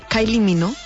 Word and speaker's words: Kylie Minogue: Que es diu Kylie Minogue. – Oh. – Crec que es pronuncia així. – Jo crec que ho ha dit molt bé.